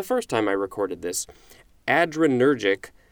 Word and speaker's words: adrenergic: But it's spelled A-D-R-E-N-E-R-G-I-C. So it looks like it's A-D-R plus energetic, but I believe it's pronounced adrenergic.